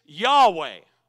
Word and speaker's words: Yahweh: But us, as New Testament believers, as Christians, the best way we can possibly pronounce it is Yahweh.